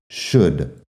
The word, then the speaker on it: should: Fíjate, should. Hay un par de cosas ahí. Primero, la SH que es sh, que es un sonido que no se usa en español, luego la U corta, e.